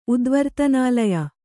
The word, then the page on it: ಉದ್ವರ್ತನಾಲಯ: Kannada